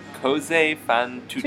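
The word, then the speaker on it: Così fan tutte: Cose fan tutte.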